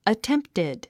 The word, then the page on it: attempted: ətémptid; アテンプティド